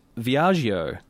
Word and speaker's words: Viaggio: Okay, so this is Fiat's new small car has been unveiled, and it's called the Fiat Viaggio.